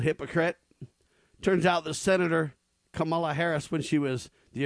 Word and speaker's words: Kamala: Kamala is how you say it, by the way. Kamala Harris. They say it's Kamala.